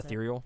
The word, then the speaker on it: ethereal: That's the earthrail, or I can't pronounce ethereal.